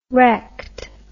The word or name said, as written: wrecked